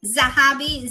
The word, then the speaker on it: Zahabi: Zahabi, Z as in zebra, A-H-A, B as in boy, I.